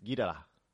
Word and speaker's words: Ghidorah: King Ghidorah. That's how we pronounce it. But if you listen to the Japanese, it's Ghidorah, King Ghidorah.